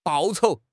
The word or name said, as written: 报酬